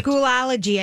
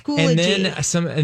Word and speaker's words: Schoology: Schoology is the program that Schoolology. – I thought it was Schoolology. – Yeah. But it is Schoology.